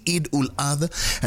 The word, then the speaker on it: Eid ul Adha: You have to say it right. Happy Eid ul Adha.